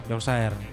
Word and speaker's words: Yorkshire: Yorkshire atau Yorkshire bacanya? – Yorkshire.